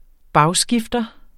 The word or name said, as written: bagskifter